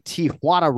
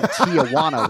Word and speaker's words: Tijuana: But, you know, I'm most proud of like pronouncing Tijuana right and not saying Tijuana when Brandon Moreno breaks through, right?